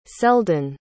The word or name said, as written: selden